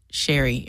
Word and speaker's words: Sherry: My name is Sherry. It rhymes with cherry.